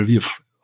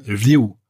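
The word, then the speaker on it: Lviv (Львів): Deswegen heißt es im russischen Lwov, aber im ukrainischen Lviv eigentlich sogar noch ein bisschen anders ausgesprochen, Lviv. Das klingt ganz anders als Lwov. Oder anderes Beispiel, Koschka, die Katze im russischen, heißt ukrainisch Kischka.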